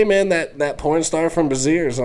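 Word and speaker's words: Brazzers: It's pronounced Braziers, dumbass.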